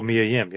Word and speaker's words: Mia Yim: Mia Yim? – Oh, Mia Yim, yep.